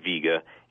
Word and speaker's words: Vega: Its name originally in Arabic was El-Wika, and it eventually got kind of translated and transmuted and so forth through the Germanic language as Wiga, or pronounced Vega, and hence we have it today.